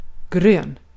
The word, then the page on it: grön: /ɡrøːn/